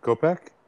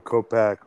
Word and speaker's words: Kopech: How do you say his last name? Kopech? – Yeah. – Michael Kopech over 5.5 strikeouts.